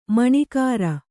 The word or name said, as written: ಮಣೆಗಾರ